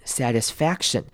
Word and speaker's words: satisfaction: 首先是 t 的部分呢，我们会稍微弱化，变成像是 d 的声音，satisfaction。好，也要注意中间 I 的念法要念成短母音 I，satisfaction 而不是念成 satisfaction。好，一样同样的要注意的，这个字里面短母音的声音还有 A satisfaction， A 的声音记得要念出来 satisfaction。好，一样很多人在念 C-T-I-O-N 的时候会很容易把 C 的声音省略掉，但是这边要特别提醒的是 C 的声音一定要咬出来 satisfaction 那个 k-shen 的 k 的声音一定要念出来 a certain satisfaction。